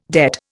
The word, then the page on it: debt: Indian